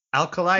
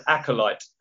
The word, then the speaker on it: Acolyte: Alkalite. – Not Alkalite. You obviously need a drink if you've gone Alkalite. You're looking for a low alcohol beverage. Acolyte. – Acolyte.